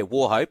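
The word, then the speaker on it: Wauchope: Now, is it Warhope? Is that how I pronounce it? Yeah, Warhope.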